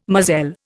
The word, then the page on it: moselle: Indian